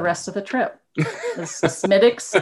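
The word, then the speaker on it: Smithwick's: And so it was a, well, they call it Smittix, but it's spelled Smithwix. – Yeah. – Smittix beer.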